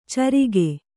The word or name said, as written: ಚರಿಗೆ